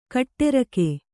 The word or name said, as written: ಕೊಠಾರಿಕೆ